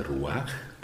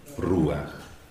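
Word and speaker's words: Ruach (רוח): Il Ruach, non dite Ruach perché vi caccio via, l'accento è sulla U, Ruach. Se no, fate finta di essere biblisti e non lo siete. Se invece siete biblisti, mettete gli accenti al posto giusto.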